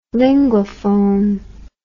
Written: Linguaphone

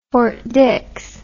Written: Fort Dix

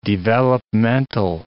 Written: developmental

